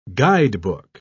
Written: guidebook

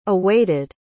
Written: awaited

